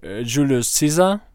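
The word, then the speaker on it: Julius Caesar: На английском он произносится как Джулиус Сиза, и, соответственно, циза созвучно со сниза, и это как бы в переводе, ну, значит чихающий.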